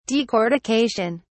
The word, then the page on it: decortication: British English